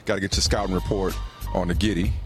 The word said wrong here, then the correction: Gritty: All right? Did I say that right? – Gritty. – The Gritty.